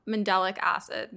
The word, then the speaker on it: mandelic acid: I want to talk a little bit more about mandelic acid.